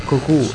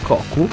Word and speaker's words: Cocko: E mi hanno anche dato una dritta sul loro nome, che appunto si pronuncia Cocu e non Cocu. Scritto C-O-C-K-O, quindi è una pronuncia particolare.